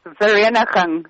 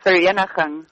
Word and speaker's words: Vereeniging: Vereeniging. – Yes. – Vereeniging.